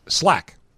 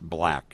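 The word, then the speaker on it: 6LACK: L-A-C-K. Six L-A-C-K. – Slack. – It's actually pronounced black is what I've learned.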